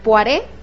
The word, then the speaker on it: Poiré: Me dijeron, ya me corrigieron sobre cómo se pronuncia este apellido, es Poiré, Alejandro Poiré.